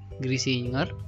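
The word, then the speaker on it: Griesinger: Wilhelm Grisi uh, eh, siapa sih bacanya? Wil Wilhelm ya Grisinger, seorang dokter di Jerman menyatakan bahwa perilaku abnormal berakar pada penyakit di otak.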